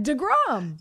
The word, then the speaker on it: deGrom: I mean, it rhymes with LeBron. – I just said DeGrom.